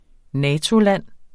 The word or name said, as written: NATO-land